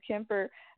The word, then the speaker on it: Kemper: Or, it's K-E-M-P-E-R, so Kemper, yeah.